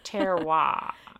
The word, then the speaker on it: terroir: Terroir.